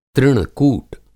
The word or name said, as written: तृण-कूट